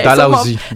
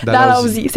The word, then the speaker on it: Dalhousie: l'université de Dal Dalhousie. Je, je sais pas que je le dis bien. Dalhousie. Dalhousie, c'est ça.